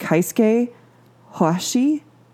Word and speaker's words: Keisuke Hoashi: but I believe it's pronounced Keisuke Hoashi, K-E-I-S-U-K-E-H-O-A-S-H-I.